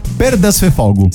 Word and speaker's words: Perdasfefogu: Infatti proprio a Nuoro, a Perdasfefogu, l'ho detto bene? Perdasfefogu. – Sì, comunque la Barbagia è il cuore montuoso e selvaggio della Sardegna centrale.